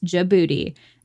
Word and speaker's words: Djibouti: Yes, it's pronounced Djibouti.